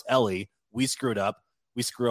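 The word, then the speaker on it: Eeli: I keep messing that up. Ellie, Eli. Well, I was trying to be funny in the moment, and I actually had studied the pronunciation guide before, just because I always screw it up. So Ellie, it's just Ellie.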